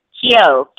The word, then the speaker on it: Kyo: But it's Nam, which is N-A-M as in Mary, Myoho, M-Y-O-H-O, Renge, R-E-N-G-E, Kyo, K-Y-O.